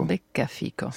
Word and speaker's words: Beccafico: Beccafico. – C'est ça, l'accent tonique est sur becca. – Puisqu'il y a, oui, c'est très bien, vous avez très bien prononcé.